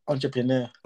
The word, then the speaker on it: entrepreneur: I can never say that word. How do you say it? – I can't even say myself. Entrepreneur. – Entrepreneur. Thank you. I have to hear someone say it before I can say it properly.